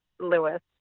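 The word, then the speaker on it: Lewes: And that's a very common thing that people pronounce it right, but it's Lewis, just like a person's name.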